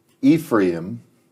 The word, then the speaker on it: Ephraim: Yeah, okay, so it's, you know, you have, it's E, it's a long E, Ephraim.